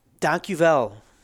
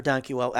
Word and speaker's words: Dankjewel: Dankjewel, mm-hmm. or Dankjewel. I always say it wrong because I want to say it with German accent.